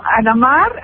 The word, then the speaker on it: Anamar: Ah, bueno, Anamar, así como se escucha, pero sin la IA, digamos, como si fuera Ana María, pero sin la IA.